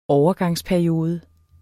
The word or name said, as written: overgangsperiode